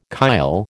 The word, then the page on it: chyle: American English